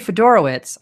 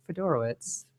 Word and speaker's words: Fedorowicz: Now, CJ Fedorowicz, I finally got this name right on my first time.